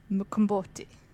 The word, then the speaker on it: umqombothi: now it's spelled U M Q O M B O T H I, but it's pronounced Mkomboti.